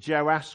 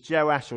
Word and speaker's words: Joash: We read in the beginning of chapter 12 that Jehoash, who's also called Joash, confusingly, you can say his name the short way or the long way, so it's like Andrew or Andy, or his Joash or Jehoash.